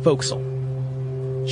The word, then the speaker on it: forecastle: The front is spelled forecastle, but it's pronounced forecastle.